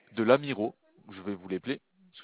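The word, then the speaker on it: Lamirault: L'Amiro, ça s'écrit L-A-M. – L-A-M, ouais. – I-R-A. – I-R-A. – U-L-T. – U-L-T, ok. – Donc ça vous donne l'Amirolt, en fait.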